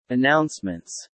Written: announcements

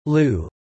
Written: lieu

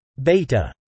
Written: beta

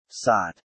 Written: sought

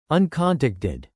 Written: uncontacted

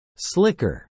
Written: slicker

